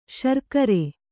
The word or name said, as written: ಶರ್ಕರೆ